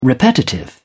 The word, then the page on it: repetitive: British English